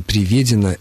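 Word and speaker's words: приведён: «Приведен», «приведено» – это грубая ошибка. Правильное ударение, причем с буквой «ё» в третьем слоге в случае мужского рода «приведен», ну, а для женского рода «приведена», но никак не «приведена».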